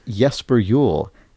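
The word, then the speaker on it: Jesper Juul: it's pronounced Jesper Jewel. As if the J's were wise."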